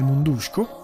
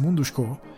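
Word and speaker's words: Mundruczó: Successivamente ho visto Pieces of a Woman di eh, Cornel Mundusco, anche qui la CZ credo diventi così, se non ho capito male questa è la pronuncia corretta, ma anzi Mundushko perché è accentato, se non ho capito male dovrebbe essere così.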